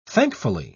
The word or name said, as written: thankfully